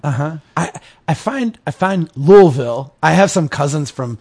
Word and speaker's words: Louisville: So I started off saying Louisville. I think right. That's the way I said it. And then my wife just corrected me over and over again till I said Louisville. But then it turns out I get up there and everyone says Louisville or something like that.